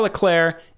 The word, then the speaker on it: Leclerc: Short version, both are fine, but Charles Leclerc is the Monegasque pronunciation.